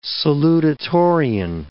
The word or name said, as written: salutatorian